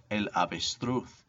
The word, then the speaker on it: avestruz: Number two, el avestruz, or in Spain, this would be pronounced el avestruz.